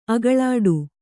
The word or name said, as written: ಅಗಲಾಡು